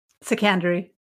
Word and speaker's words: Sekandari: Sekandari.